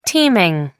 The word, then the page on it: teaming: American English